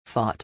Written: fought

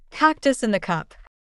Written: cactus in the cup

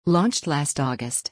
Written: launched last August

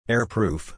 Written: air-proof

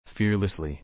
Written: fearlessly

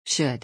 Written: should

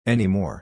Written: anymore

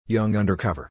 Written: young undercover

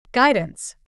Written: guidance

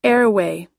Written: airway

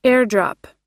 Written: airdrop

